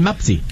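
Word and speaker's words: Mpasi: No va a sonar muy conocido porque la pronunciación es Lionel Mapsi.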